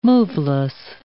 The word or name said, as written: moveless